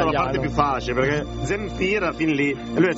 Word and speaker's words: Zemfira: Allora io la pronuncio così: di Zemifra. – No, di Zemfira.